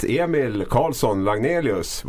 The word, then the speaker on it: Emil Karlsson Lagnelius: Och på kort tid så har vi fått hit Emil Karlsson Lagnelius. Var det rätt uttalat? Ja, helt rätt. Mycket snyggt.